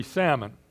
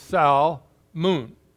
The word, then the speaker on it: Salmon: The problem with that name is, do you pronounce the L or not? In English, we don't. So it would be salmon. But in Hebrew, they do. So, and the emphasis is on the first part, so it's Salmon. And that's how the Hebrew says it.